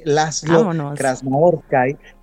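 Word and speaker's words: László Krasznahorkai: Pues lo pronunciaste increíble, Laszlo Krasznahorkai.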